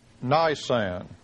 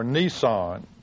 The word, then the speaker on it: Nisan: On the 15th of Nisan, or Nisan. Avoid the second pronunciation.